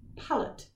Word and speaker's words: Pallot: So, Bill, B-I-L-L. – Got that. – A G, then a B, and then Palot, P-A-L-L-O-T. But I think I'm going to pronounce it Palo.